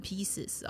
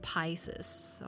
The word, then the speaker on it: Pisces: 千万不要念成 Pieces 哦，那就是碎成一片一片的。它是 Pisces， OK，它是 12星座里面最末的一个。